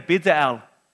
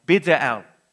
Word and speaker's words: Bezalel: Everyone say Bezalel. Oh, yeah, I just wanted to, so I didn't feel so bad when I said it wrong. Bezalel.